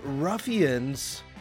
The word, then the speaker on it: Ruffyunz: The band is called Ruffians, spelled R-U-F-F-Y-U-N-Z. Like that Pittsburgh pronunciation, but they are not local.